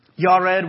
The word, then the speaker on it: Yared: Yared, we would say Jared.